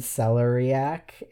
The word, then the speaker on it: celeriac: I've always said celeriac in my head. – According to Google, it is celeriac.